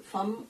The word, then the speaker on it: femme: Das ist mit so einem FEM-M-E e geschrieben, also so dass man es sowohl Fam als auch Fem lesen kann. Wobei es ja auch Leute gibt, die F-E-M-M-E Fem aussprechen und Leute, die F-E-M-M-E Fam aussprechen.